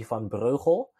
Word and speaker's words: van Breugel: So as you, in the beginning, try to pronounce my surname. It's pretty hard. It's super Dutch. So my full name is Jesse van Breugel.